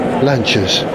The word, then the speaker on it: Lancia: Four magnificent Lancias. And it is pronounced Lancia. Don't let anyone tell you it's Lancia. I know that because Paolo Pininfarina himself said Lancia.